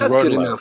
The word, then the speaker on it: Rotolo: Anyway, well, I'm going to say my agent was her agent on that book, and she pronounces it Rotolo. So. – Oh, great. Well, then it's Rotolo. It's Rotolo from now on. It's definitely Rotolo.